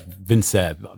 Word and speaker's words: Vincere: Vincere, if I'm pronouncing that right, yeah. – Yeah, yeah. I don't know how to. Yeah. It's Vincere, V-I-N-C-E-R-E. – Yeah, that's right.